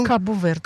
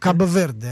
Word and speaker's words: Кабо-Верде: Сегодня еще одни авторы, и самый главный до сих пор сенсации, сборная Кабо-Верде, да, так правильно произносить эту страну? – Кабо-Верде. – Кабо-Верде.